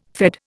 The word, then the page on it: Fed: Indian